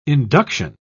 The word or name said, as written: induction